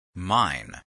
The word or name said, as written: mine